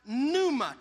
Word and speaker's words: pneuma: In the New Testament, the word for Holy Spirit was a word in which the P was silent, and it's pronounced pneuma. Turn to your neighbor and say pneuma.